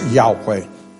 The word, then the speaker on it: Yahweh: that YHWH, you would pronounce Yahweh, is found in the Old Testament in places.